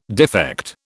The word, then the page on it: defect: British English